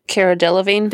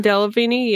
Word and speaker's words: Delevingne: Cara Delevingne. – Delevingne, yeah.